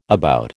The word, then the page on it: about: American English